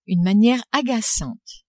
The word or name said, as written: une maniere agacante